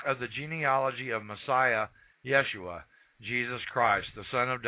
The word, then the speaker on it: Yeshua: However, at this time, his name was actually pronounced Yeshua.